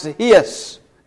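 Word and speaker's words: his (εἷς): In Greek, the word EIS is pronounced his, H-E-I-S.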